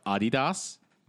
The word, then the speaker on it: Adidas: And by the way, it's pronounced Adidas. – No. – It's pronounced Adidas. – No. – According to the company itself, it is Adidas.